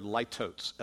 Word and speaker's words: litotes: Looks like the word litotes, L-I-T-O-T-E-S. But it's pronounced litotes.